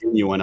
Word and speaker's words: genuine: You know, it's just the wrong emphasis. But it's pronounced genuine, apparently.